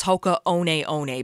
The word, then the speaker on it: Hoka One One: And not that it matters, but the official way that you pronounce it is Hoka One One, because I did this research.